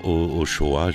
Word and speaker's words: Ochoa: Eliades Ojo, la prononciation Ochoa, je veux dire, enregistré en seulement 6 jours au studio Egrem.